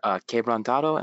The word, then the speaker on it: Quebrantado: Quebrantado, am I pronouncing that remotely correctly? – Yes. Okay. Good job.